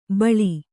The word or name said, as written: ಬಳಿ